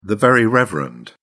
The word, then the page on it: very reverend: British English